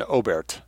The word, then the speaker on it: Oberth: He remembers studying the 1920s and 30s work of the German pioneer of rocket flight, Hermann Oberth, O-B-E-R-T-H. Hermann Oberth, pronounced in German. Oberth, we'll anglicize it.